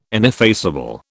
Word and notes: ineffaceable: British English

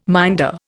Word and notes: minder: Indian

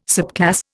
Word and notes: subclass: Indian

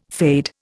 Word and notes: fade: Indian

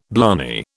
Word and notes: blarney: British English

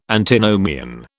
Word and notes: Antinomian: British English

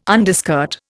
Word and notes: underskirt: Indian

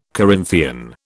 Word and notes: Corinthian: British English

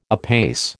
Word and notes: apace: American English

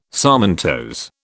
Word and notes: sarmentose: British English

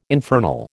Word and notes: infernal: American English

